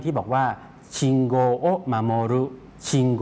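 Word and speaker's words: ชิ ง โ ก (信号): ออกเสียงว่าโกโกอุเนี่ยจะออกเสียงรวบยาวนะครับออกเสียงว่าโกเพราะฉะนั้นคันจิ 2ตัวแรกนะครับเป็น 1คำนะครับออกเสียงว่าชิงโกชิงโกครับ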